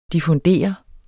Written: diffundere

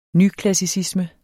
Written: nyklassicisme

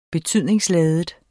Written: betydningsladet

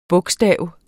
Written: bogstav

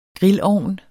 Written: grillovn

